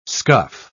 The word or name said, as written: scuff